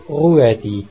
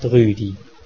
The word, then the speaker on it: Ruedi: Ein geschriebenes UE wird nicht Ü, sondern UE ausgesprochen, mit Betonung auf dem U. Der Schweizer Rudolf ist also Ruedi, nicht Rüdi.